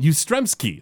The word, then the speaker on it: Yastrzemski: But Milhouse comes in, and he wants the Carl, how do you pronounce that name? – Carl Yastrzemski? – Yastrzemski. That's how it is.